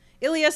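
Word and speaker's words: Ilie: And Raimondo, who is one of the best shot stoppers of penalties in the league, if not the best, was beaten by Illy. Is that how we say his name? Ilya Sanchez on the ensuing kick. That sounds good.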